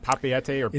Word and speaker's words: Papeete: Is it Papeete? – It is.